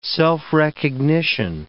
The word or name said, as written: self-recognition